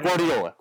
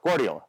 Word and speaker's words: Guardiola: One, tactically, he's been described as a middle ground between our friend Jose Mourinho and Pep Guardiola, which I – did I even come close to pronouncing that right? Just going to call him Pep. – Or Guardiola. – Guardiola. There we go.